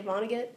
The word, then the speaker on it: Vonnegut: But sometimes if it's like I'm looking for okay, I probably should pick a better example because I can't say Vonnegut is how you say his last name. – Yeah, I think so.